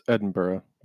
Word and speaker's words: Edinburgh: Like, well, how do you say Edinburgh? Edinburgh? Edinburgh? – It's Edinburgh. Yeah.